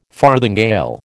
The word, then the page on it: farthingale: American English